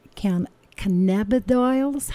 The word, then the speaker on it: cannabidiol: And addressing it this way, we're going to be talking about cannabidiols.